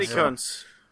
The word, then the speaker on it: Gutekunst: I never know how to pronounce that guy's name. Brian Gutekunst. – Yeah, I see it on Twitter all the time. I've never heard it said. – Gutekunst.